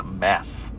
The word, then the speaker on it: Mads: It's spelled Mads, but apparently you pronounce it Mass.